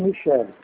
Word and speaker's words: Michele: É, se escreve Michele. É italiano, por isso.